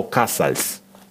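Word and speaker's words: Casals: Сегодня день рождения выдающегося каталонского виолончелиста, дирижера, композитора и музыкально-общественного деятеля Пабло Казальса – Пабло Касальс. Я сказал это. И, и по-русски, и по-испански звучит примерно одинаково.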